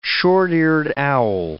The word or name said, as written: short-eared owl